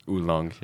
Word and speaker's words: oolong: Is that how you say it in English? – Yeah. I think it's oolong, yeah.